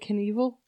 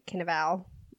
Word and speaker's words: Cannavale: Is it Knievel? – No, it's like Cannavale.